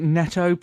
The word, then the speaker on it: Netto: Yeah, I call it Nito. A lot of people pronounce it Netto, probably, I think that's a bad store with a little dog for a symbol, and it's N-E-T-T-O.